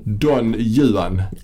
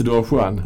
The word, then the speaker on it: Don Juan: Och så, så eh, åkallar han då eh, nota bene uttalet här nu. Mm. Don Juan. Ah. Inte Don Juan.